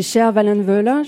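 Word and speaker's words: Sjöwall Wahlöö: c'est Cher Wallenvöla. Je ne sais pas si je le prononce correctement. Parfait.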